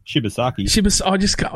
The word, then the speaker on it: Shibasaki: Jamat Shibarsky. That's terrible. I do like the signing. Yeah, how do you say it? – Shibasaki.